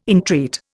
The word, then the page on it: entreat: Indian